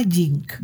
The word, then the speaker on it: GINK: La nevería se llama Jink. Jink. ¿Cómo se escribe Jink? Con G-I-N-K.